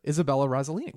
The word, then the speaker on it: Isabella Rossellini: Isabella Rossellini.